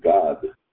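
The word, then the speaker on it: Gad: We pronounce it similar to the English word God, G-O-D.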